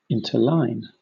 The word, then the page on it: interline: British English